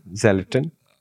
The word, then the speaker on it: Xleton: So the platform is called the Xleton mm. – with an X. – What? – Yeah. Ze- oh, skeleton. Xleton.